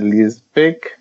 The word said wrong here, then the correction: Leipzig: Leipzig. You know, Leipzig, yeah. Thank you.